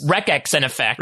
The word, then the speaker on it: Wreckx-n-Effect: It's spelled Wreckx-n-Effect. So I don't know how to pronounce it. – Wreckx. – Wreckx-n-Effect. – Wreckx. – Like a Wreckx.